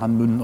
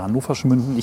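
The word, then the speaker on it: Hann. Münden: Es gibt beide Sprechweisen, Hannmünden oder Hannoversch Münden.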